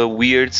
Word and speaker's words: Wyrd: E essas três irmãs são conhecidas como irmãs do, do destino ou em inglês the Weird Sisters o som parece com weird de estranho, mas se escreve diferente, com W-Y-R-D.